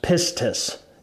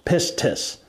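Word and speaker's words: pistis (πίστις): It is the Greek word pistis, P-I-S-T-I-A-I-S, pistis. It's pronounced pistis. You don't actually make the st sound, it's pistis.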